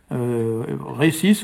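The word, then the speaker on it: resis: Moi je l'écris avec un seul S parce que c'est pas nécessaire d'en mettre deux, puisque en breton le S se prononce toujours S, même s'il est entre deux voyelles, donc autant aller au plus simple. D'ailleurs, on le voit souvent écrit, récis aussi, avec un seul S.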